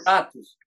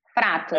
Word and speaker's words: Fratus: Fratus, né, que se pronuncia, né? Fratus. Fratus. – Fratus.